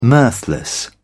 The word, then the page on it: mirthless: British English